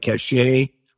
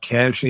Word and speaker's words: Cache: It's spelled C-A-C-H-E. So it looks like cachet, but it's pronounced Cash Agency.